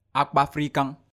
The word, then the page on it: akpafrikan: Efik